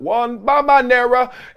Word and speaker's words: Wembanyama: how you say his name? Yep, Wembanyama, and whether he's had the chance to see the likely number one overall pick in the draft play before.